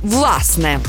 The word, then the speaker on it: власне: Наголос на першому складі «власне».